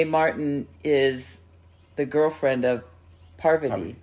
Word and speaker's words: Parvati: I have to say her name correctly because it's hard. I always want to say Poverty. – Yeah, Parvati.